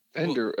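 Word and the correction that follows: Vander Esch: Well, how do you say it? – Van Der Esch. – Yeah, I mean, that. – Leighton. Leighton Van Der Esch.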